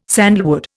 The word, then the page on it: sandalwood: Indian